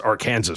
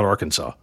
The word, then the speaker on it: Arkansas: And that's and it's pronounced Arkansas, not Arkansas.